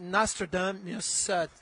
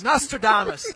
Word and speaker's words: Nostradamus: Nostradamus. I got it right. Because in French, we put the emphasis on the different syllables. Nostradamus and not Nostradamus.